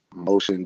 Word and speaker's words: Moshan: My IG page is Moshan DTX.